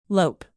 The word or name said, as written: lope